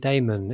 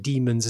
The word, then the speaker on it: Daemon: But I mean, supposed to be pronounced Demon. Anyway, because it's possibly Diamond, but I mean, it's never pronounced like that.